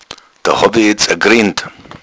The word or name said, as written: griend